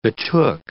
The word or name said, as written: betook